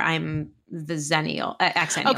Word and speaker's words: Xennial: That's a Xennial.